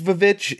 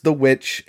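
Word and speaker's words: The VVitch: It is not pronounced Vavitch. It is pronounced The Witch.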